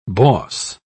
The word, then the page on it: boss: American English